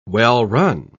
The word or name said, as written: well-run